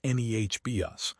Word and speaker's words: Nebius: NVIDIA has taken a 9.3% stake in Nebius, pronounced NEHBS, a Dutch AI infrastructure firm.